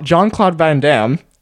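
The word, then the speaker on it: Jean-Claude Van Damme: John Cla- John Claude Van Damme.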